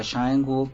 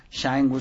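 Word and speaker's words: Cha Eun-woo: Shang-woo se escribe Sha, como de cha-cha-cha. E-U-N. Ajá. Woo con doble O.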